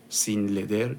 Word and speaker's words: Sin.Leather: سین نقطه لدر تلفظ میکنم S I N نقطه L E A T H R سین لدر چرم